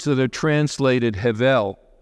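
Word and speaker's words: hevel (הבל): And I'm not a Hebrew scholar, but I looked it up to get the correct pronunciation, and it's hevel. It's like you're clearing your throat.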